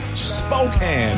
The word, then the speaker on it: Spokane: Spokane, it's pronounced.